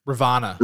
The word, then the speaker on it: Ravonna: Ravonna.